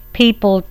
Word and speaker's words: pipal: It's spelled P-I-P-A-L, peepal tree, or the banyan tree.